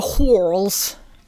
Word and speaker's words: whorls: Now, it's hard to know how to actually pronounce this as an American because Sherlock is British, so it has the extra step of being in a British accent. – Yeah, so extra. – But it's this word that's spelled W-H-O-R-L-S.